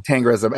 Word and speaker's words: Tengrism: Right, Attila the Hun was a Tengridism. I don't even know that how to say it. Tengridism or Tengridist. – It's Tengrism.